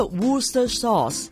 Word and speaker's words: Worcestershire: 英式的读法不是隻隻字都读出来的， WORCESTERSHIRE Worcester Worcestershire。其实很多英国地名都保留古老串法的，但是呢发音缩短了，最简单的记法就是整串字母不用读 CES 的，就这样读 Worcester sauce Worcester sauce，大致上明白了。